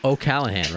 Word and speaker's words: O'Callaghan: O'Callaghan, right? – That's perfect. – Oh, okay, then that's fine. I wasn't sure if the G was silent or not, so I didn't want to butcher it.